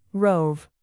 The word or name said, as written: rove